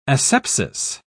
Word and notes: asepsis: British English

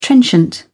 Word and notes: trenchant: British English

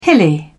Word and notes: hilly: British English

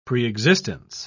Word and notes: preexistence: British English

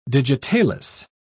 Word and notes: digitalis: British English

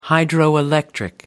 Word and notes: hydroelectric: American English

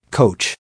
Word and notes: coach: American English

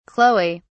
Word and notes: chloe: British English

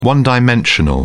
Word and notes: one-dimensional: British English

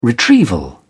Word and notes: retrieval: British English

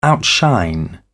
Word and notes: outshine: British English

